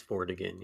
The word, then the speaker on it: Vortigern: was that the first story written about Merlin from Geoffrey of Monmouth comes from a story about a chieftain called Vortigern or Vortigern. I'm not exactly sure how you're supposed to pronounce it. – I think it's Vortigern, yeah.